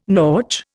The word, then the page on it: nautch: Indian